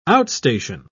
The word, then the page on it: outstation: British English